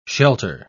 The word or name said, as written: shelter